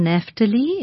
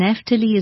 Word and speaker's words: Naphtali: Or if you just put my name on on YouTube or Google, you will find it, Rishon Naftali. It's spelled, Rishon is R-I-S-H-O-N, Rishon. And Naftali is N-A-P-H, P for Peter. So it's Naftali, N-A-P-H-T-A-L-I. Rishon Naftali, if you put that name in also, you'll reach my website.